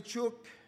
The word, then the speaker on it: Chuuk: So welcome to Chuuk, or also known as Truk, because during the time when the Japanese colonized the island of Chuuk, they could not pronounce the CH, so they always refer as a Truk. So when other people came, they at the course of time, the word or the island name Chuuk became Truk.